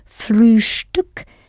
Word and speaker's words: Frühstück: Especially if you remember to pronounce the st in Frühstück as though it has a sh before the t.